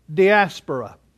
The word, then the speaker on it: diaspora: The Greek word is diaspora. Let me spell it. It's D-I-A-S-P-O-R-A. D-I-A-S-P-O-R-I-A, diaspora.